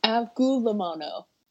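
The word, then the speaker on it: avgolemono: I googled it. It's avgo limono. – Avgo limono. – Yeah, it's Greek for egg and lemon.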